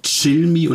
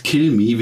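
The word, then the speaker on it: Cilmi: Die australische Popsängerin und Songwriterin Gabriella Lucia Tilmi, ausgesprochen Chill Me und nicht Kill Me, wie ich das gedacht hatte, kam 1991 in einem Vorort von Melbourne auf diese wundervolle Erde.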